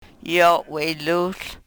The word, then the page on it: yo we-luhl: Yurok